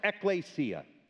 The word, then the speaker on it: ekklesia: It's a Greek word that's pronounced ekklesia. Ek means out from and towards, and kaleo means to call.